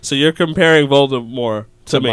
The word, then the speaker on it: Voldemort: Actually, supposedly we've all been pronouncing Voldemort wrong. – Yeah, apparently it's Voldemort. – The T is silent, sir. – Believe it or not. – Voldemort. – Voldemort. – Voldemort. – Voldemort. Like in Colbert.